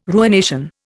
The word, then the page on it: ruination: Indian